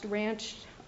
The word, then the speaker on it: Ranscht: And to the extent that, I believe it was in the reply that petitioner cited People versus, I believe it's pronounced Ranch, R-A-N-S-C-H-T, to argue that all offenses have a mens rea, including these strict liability statutory rape offenses.